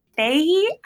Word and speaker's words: Fahey: Fahey.